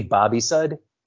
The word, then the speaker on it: Bobysud: It's technically Bobisud. So just think the name Bobby and then suds as if in soap.